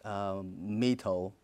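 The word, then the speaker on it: Mỹ Tho: Mai Tho.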